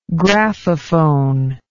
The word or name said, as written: graphophone